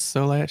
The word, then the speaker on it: Soleil: Let me bring out the French. Soleil. Yeah.